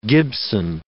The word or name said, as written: Gibson